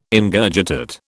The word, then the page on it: Ingurgitate: British English